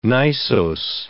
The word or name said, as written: gneissose